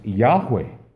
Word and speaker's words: Yahweh: we believe that the, that the, the, vo- the vowel pointing means that the, the word uh, should be pronounced Yahweh, which means he is or he will be.